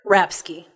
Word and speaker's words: Rapske: Rapsky.